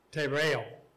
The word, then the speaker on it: tereo: T-E-R-E-O. Tereo.